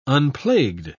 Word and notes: unplagued: British English

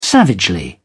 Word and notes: savagely: British English

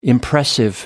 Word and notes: impressive: American English

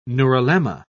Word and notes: neurilemma: British English

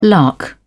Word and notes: lark: British English

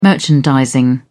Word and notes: merchandising: British English

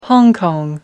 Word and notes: hong kong: American English

